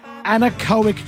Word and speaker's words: anechoic: It's pronounced anechoic chamber. A-N-E-C-H-O-I-C. I'm going to say it's their anechoic chamber.